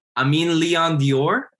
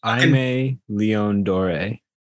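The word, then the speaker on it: Aimé Leon Dore: Amin Leon Dior? Is that how you say it? – Aimé Leon Doré, I think. Or Leon Doré, one of the two, ALD.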